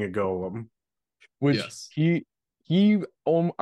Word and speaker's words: golem: Yeah, the proper the proper Hebraic pronunciation is golem.